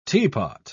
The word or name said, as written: teapot